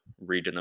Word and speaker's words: Reading: called Reading, which is spelt as Reading, so reading a book, but you say it Reading, just to confuse you guys even more.